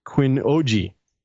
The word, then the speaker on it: Qinuoji: I think it's pronounced Qinuoji. So it's Q-I-N-U-O-J-I.